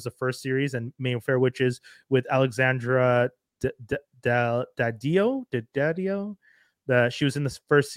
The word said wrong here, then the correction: Daddario: I always feel like I say her name wrong. So I'm gonna, let me just actually look it up. Alexandra Daddario, if anyone knows it.